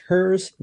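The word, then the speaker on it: Herszenhorn: You're pronouncing it correctly, but it's really not hard at all. Think about it as three words. Hers, not his, Zen like the Buddha, horn like a trumpet.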